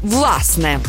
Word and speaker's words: власне: Наголос на першому складі «власне».